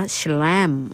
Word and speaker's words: Chalame: แชลมอ่านว่าแชลมนะคะ CHALAME ลูกชิ้นไก่เอ็นไก่ตราแชลมอ๋อถ้าอ่านเป็นภาษาไทยก็คือแชลมนะคะถ้าภาษาอังกฤษก็แชลม